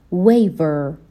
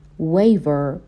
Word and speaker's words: waver: به تلفظ کلمه waver دقت بکنین. ما W رو داریم و V رو داریم که توی انگلیسی تلفظشون کاملا با همدیگه متفاوت هستش. حرف W جوری تلفظ میشه که دندونهای بالا به لب پایین نباید برخورد بکنن. لبها باید قنچه بشه و حرف V مثل V فارسی تلفظ میشه. سعی کنین بلند تمرین بکنین.